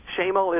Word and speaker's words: Schemel: Schemel is correct. Long A.